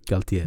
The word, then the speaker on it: Galtier: Han, eh, hur, hur var uttalet? Var det nice eller? Galtier. Det är nice.